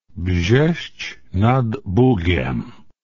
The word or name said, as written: Brzesc nad Bugiem